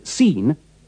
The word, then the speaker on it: scene: Remember to pronounce the SC in scene with the sound of the S in C.